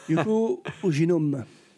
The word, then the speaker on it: Yoko Oginome: Yoko Oginome.